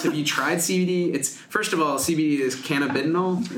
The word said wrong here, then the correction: cannabidiol: Is that correct? – I guess. – Or how do you say it? – This is my pronunciation is, now I'm going to say it wrong. cannabidiol. – Cannabidiol. Okay. – Yes. This is the Canadian pronunciation that you hear at the medical conferences.